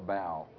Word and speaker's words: bough: For instance, what is a bow, a B-O-U-G-H, or a bow, some people call it? I guess it's probably properly pronounced a bow.